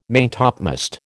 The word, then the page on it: maintopmast: American English